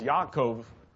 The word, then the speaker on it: Yaakov: Jacob is Yaakov in Hebrew.